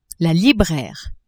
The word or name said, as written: libraire